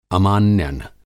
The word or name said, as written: अमान्यन